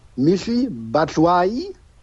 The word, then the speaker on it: Michy Batshuayi: Laut der Aussprache, Auskunft von Transfermarkt.de heißt der Junge Michy Batshuayi und kommt vom FC Chelsea leihweise für ein halbes Jahr.